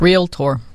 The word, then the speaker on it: Realtor: Realtor, I mean, everybody's is hung up on this realtor. – It's got to be COVID. – How do you say it? – Realtor. Two syllables.